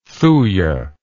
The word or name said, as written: thuya